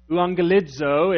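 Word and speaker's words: Euangelizo (εὐαγγελίζω): Evangelism is really a Greek word. Euangelizo is the way you pronounce it in Greek.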